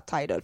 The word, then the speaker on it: Tidal: Tidal.